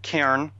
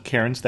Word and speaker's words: Cairns: And just so you know, because Dave and I talked about this afterwards, if he slips in and calls it Cairns, that's because that's the way it's spelled. If we call it Cairns, that's because that's closer to the local pronunciation to the extent that either one of us can do an Australian accent.